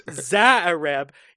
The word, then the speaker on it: Xuereb: And the host of this little drug and hooker fueled shindig is Eddie Dominguez, played by Emmanuel Zaareb. His last name is spelt XUEREB. That was another one where I had to look up the pronunciation.